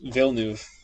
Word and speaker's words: Villeneuve: Gilles Villeneuve is Villeneuve.